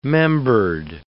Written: membered

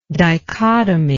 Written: dichotomically

